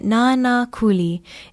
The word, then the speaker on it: Nanakuli: It is spelled N-A-N-A-K-U-L-I with stresses on both the first and second syllable. Say it Nana Kuli.